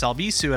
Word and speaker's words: Albisu: You can reach me at Alex Albisu at all places. Last name is spelled A-L-B-I-S-U.